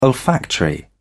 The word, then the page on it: olfactory: British English